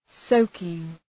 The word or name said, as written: soaking